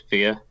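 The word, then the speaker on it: fíor: So if you're describing something as true, the translation of true is fear. – Wow. – F-I-O-R, but it's pronounced identically to F-E-A-R in English.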